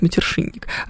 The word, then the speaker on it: матершинник: А, интересуется, как правильно говорит матершинник. А, говорим мы через ша, а пишем через ща.